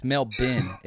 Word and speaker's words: Melbourne: I've been said it's Melbourne is the way the Aussies pronounce it.